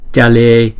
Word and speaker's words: chale: Chữ C chúng ta đọc là cha cha. Còn chữ J chúng ta đọc là ja ja. Đó, cho nên chúng ta phân biệt là chữ này là chale achale muchale.